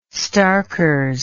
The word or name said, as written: starkers